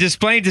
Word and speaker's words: Des Plaines: Yeah, and Des Plaines is too. It's like it's one of those like French words that like you feel like the S should not be pronounced, but it is.